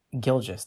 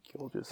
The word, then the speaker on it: Gilgeous: I don't know how to say the first part of his last name. No. – Shai Gilgeous-Alexander? – Gilgeous. There we go. That's what it was. Yeah. – Gilgeous.